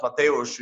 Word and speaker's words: Mateusz: It's Matthews. It's like it's pronounced Mateusz.